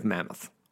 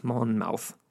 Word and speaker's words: Monmouth: And it's funny because the round table doesn't actually appear until Joffrey of Mammoth, Monmouth.